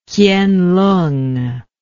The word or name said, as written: kienlung